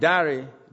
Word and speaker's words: Dare: My name, Dare, D-A-R-E, could often be pronounced as Dare.